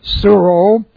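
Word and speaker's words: suro (σύρω): Okay, we have the present active indicative of suro, which looks like this in the Greek, S-U-R long O, suro.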